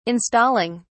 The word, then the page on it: installing: British English